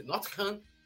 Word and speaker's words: Nottingham: É assim que se pronuncia. Nottingham, Nottingham Forest. Perfeita pronúncia, quase um inglês nato.